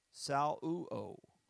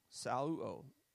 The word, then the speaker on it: saleuo: S-A-L-E-U-O. Sal-u-o. Sal-u-o. S-A-L-E-U-O.